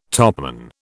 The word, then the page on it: topman: British English